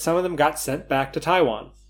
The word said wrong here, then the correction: Taiwan: I said that very strangely. Got the emphasis on the wrong syllable. Some of them were sent back to Taiwan.